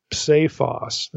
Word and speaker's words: psephos: The Greek here is psephon leucain, and Owen writes this, psephos, that's P-S-E, long E, P-H-O-S.